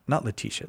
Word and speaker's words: Laetitia: Or Laetitia, is that how you say it? – That's how I say it.